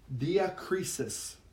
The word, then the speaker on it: diakrisis: So this word discernment in the Greek is pronounced like this: diakrisis, or diakrisis. Diakrisis.